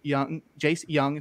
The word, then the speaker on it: Jung: You got Jace Young, it's spelled Jung, who went 12th overall to the Tigers.